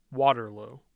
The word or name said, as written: Waterloo